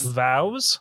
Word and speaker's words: vows: Vowels. – Not vowels. – Vows? – Vows. Renewal of Vows. – Renewal of Vows. – Yes. – Okay. – Unique. – It's like cows, but with a V.